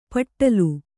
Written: ಪಟ್ಟಲು

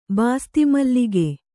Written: ಬಾಸ್ತಿಮಲ್ಲಿಗೆ

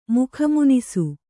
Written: ಮುಖಮುನಿಸು

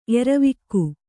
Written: ಎರವಿಕ್ಕು

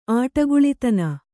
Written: ಆಟಗುಳಿತನ

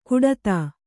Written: ಕುಡತಾ

